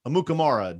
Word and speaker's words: Amukamara: I'm not going to hit the breaking news though, but Prince Amukamara.